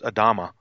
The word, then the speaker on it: Adama: It's from 119, which is Adam. So it's actually pronounced Adama.